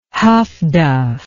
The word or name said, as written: huff duff